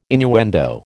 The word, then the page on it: innuendo: American English